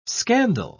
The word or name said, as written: scandal